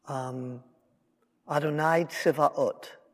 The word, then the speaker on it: Adonai Tzivaot: Adonai Tzivaot is how it's said in Hebrew.